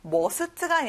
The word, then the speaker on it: must: 자, must가 아니라 아 소리가 나요. must.